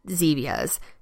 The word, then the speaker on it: Zevia: And I do just want to say, if you are this person that loves your Cokes or your Diet Cokes, check out Zevia's. Okay, it's spelled with a Z, Z-E-V-I-A. So it's supposed to be like stevia, but Zevia.